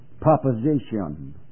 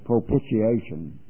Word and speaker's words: propitiation: I can remember, this seems kind of humorous, but I think in pronouncing this word so often, I've heard people say, someone would say, proposition for propitiation. And they had to be corrected, and that's not the way you pronounce that word, because that word has the meaning of sacrifice or mercy seat.